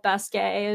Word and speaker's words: Basquiat: I used to pronounce it Basquiat. We've also talked about this in past episodes on the podcast, but Jean-Michel Basquiat is what I'm going to go with.